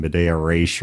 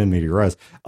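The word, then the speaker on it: in medias res: I think I said Media Res was actually pronounced in Medea Race or some weird thing like that. I don't know. The point being, you pedants can correct me on the forums if you want, but the popular pronunciation being in Media Res, you start somebody in the middle of the action, right, is what that means.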